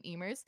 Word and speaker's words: Eimear: But there's this whole underground war between Emers. So the way I spell it, E-I-M-E-A-R, and the other spelling is E-M-E-R.